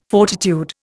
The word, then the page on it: fortitude: Indian